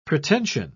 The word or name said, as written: pretension